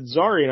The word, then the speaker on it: Zarian: Yes, that planet was like, how you pronounce it, Zarian, I like Caesarian,